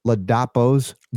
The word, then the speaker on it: Ladapo: Health agencies have dismissed Florida Surgeon General Joseph Ladapo's guidance.